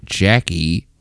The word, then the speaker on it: Jacque: And we're going to do that so we can meet a journalist from Nashville named Jackie Srugi. – Jackie Srugi. Yes. I'm already a fan. – Her name is spelled J-A-C-Q-U-E. – Sure. – And sure. – Srugi is spelled S-R-O-U-J-I.